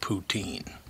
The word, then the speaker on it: Putin: By the way, apparently in France, Putin is pronounced poutine.